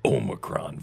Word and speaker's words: Omicron: But he stressed that he doesn't mean things could change as the nation and the world learn more about the Omicron variant. – Is that how you say it? – That is.